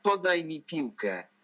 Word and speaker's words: Podaj mi piłkę: Podaj mi piłkę.